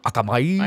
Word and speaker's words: Akamai: But Akamai, I can't even pronounce their name, A-K-A-M-A-I, Akami, Akamai. – Akamai. – Akamai. – I would say Akamai.